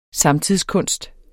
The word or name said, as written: samtidskunst